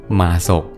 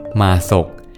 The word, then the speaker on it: มาศก: สวัสดีครับพบกับรายการคำวัดวันนี้ขอเสนอคำว่ามาศกมาศกสะกดด้วยมอม้าสละอาสอเสือกอไก่อ่านว่ามาศกมาศก